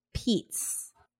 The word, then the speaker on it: Pietz: So at Bally's Total Fitness, she met David Peets, and it's spelled strange. – Spelled P I E T Z.